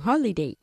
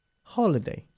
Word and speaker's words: holiday: Hay que enfatizar un poquito más la primera sílaba de la palabra holiday. No holiday, sino holiday. – Holiday.